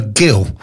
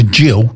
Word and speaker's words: Gill: And it kept calling her Gill. Yeah. But her name is G-I-L-L, as in, like, Gillian. So I had to change it to Jill, J-I-L-L, to get AI to pronounce it properly.